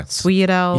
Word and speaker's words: sfogliatelle: You got to get the F in front of the S, which is it doesn't come out. – But if you're going to pronounce it the way it's spelled, it would be sfogliatelle.